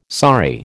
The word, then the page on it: saree: American English